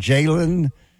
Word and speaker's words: Jalen: Is it Jalen and it's spelled J-A-L-E-N. Is that correct? Jalen? – Jalen Daniels, not to be confused.